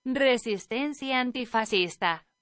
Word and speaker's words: Resistencia Antifascista: And the name of the track is Resistencia Antifascista or Resistencia Antifascista. – There you go. That is a better pronunciation.